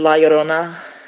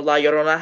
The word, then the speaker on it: La Llorona: Okay, I also did La Llorona, and I've been looking at it all day, and I knew for years that the two L's in Spanish words make a Y sound, but no one pronounces it that way.